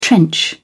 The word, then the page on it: trench: British English